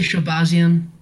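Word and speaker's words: Shahbazyan: Man, you have any idea how to pronounce that? – I just say Shabazian. – Oh, damn, you got it down.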